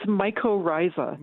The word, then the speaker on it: mycorrhiza: And what's the pronunciation, by the way? It's it's mycorrhiza. – Mycorrhiza. – Yeah, myco meaning fungus and rhiza meaning root, and it's the relationship between the fungus and the root that that word describes.